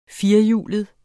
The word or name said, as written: firehjulede